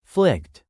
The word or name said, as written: flicked